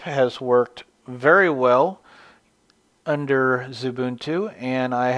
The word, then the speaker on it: Xubuntu: And when I did that, I chose the Ubuntu variant known as Zubuntu, which is spelled X-U-B-U-N-T-U.